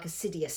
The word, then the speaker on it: Odysseus: Odysseus. – It sounds a bit more like Assidius, something like that. – Odysseus. – Sounds better, yeah.